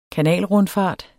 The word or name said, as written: KANALRUNDFART